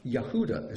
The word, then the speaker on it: Yehuda: Yehuda is how it's pronounced.